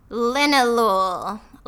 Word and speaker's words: linalool: And its name rhymes with the city where the Beatles got started. – Linalool. Like Liverpool.